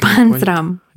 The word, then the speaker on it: Панцрам: Панцарма. Я так Панцрам.